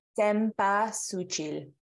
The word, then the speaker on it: Cempasúchil: Cempasúchil.